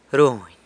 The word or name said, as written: runy